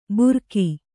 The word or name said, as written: ಬುರಕಿ